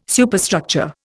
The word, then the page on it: Superstructure: Indian